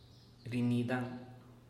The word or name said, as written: rinidan